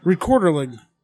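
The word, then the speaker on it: Rekorderlig: I brought a premium Swedish hard cider pronounced Rekorderling.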